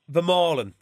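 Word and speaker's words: Vermeulen: Vermaelen, that's a better pronunciation of it.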